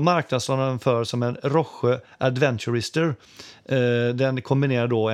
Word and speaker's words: Rohje: Eh, sedan tänkte jag bara snabbt svepa förbi ett märke som heter Roche. Tror jag det uttalas. R-O-H-J-E.